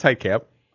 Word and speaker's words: Hieghcamp: I think it's Hike Camp. – Okay. – And it's spelled H-I-E-G-H-C-A-M-P. – Hike Camp. – Gamp? What? – Hike Camp. H-I-E. Usually, I could see the H-E-I, but no.